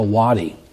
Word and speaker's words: wadi: W-A-D-E. We would pronounce it Wade, but it's a wadi.